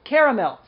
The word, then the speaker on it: caramel: Caramel. C-A-R-A-M-E-L. – Caramel. Yeah, caramel. – Caramel. Not caramel. Not caramel. Not caramel. It's caramel. Okay? Caramel. That's what it is. That's the word.